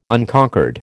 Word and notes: Unconquered: American English

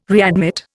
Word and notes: readmit: Indian

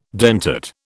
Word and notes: dentate: British English

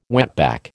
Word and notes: wetback: American English

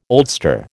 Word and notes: oldster: American English

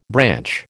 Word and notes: branch: American English

